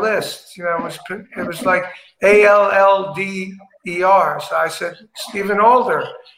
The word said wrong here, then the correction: Adler: And they said, no, it's Adler.